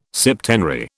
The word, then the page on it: septenary: British English